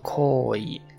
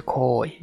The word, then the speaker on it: 好意: 然后，嗯，这个好意呢，它读作 koi 啊，恋恋字呢，就是这个恋爱的恋呢，读作 koi 啊，读起来很相似。但是这个好意呢，它有一个拉长的一个声音，就是一个是 koi，一个是 koi 啊。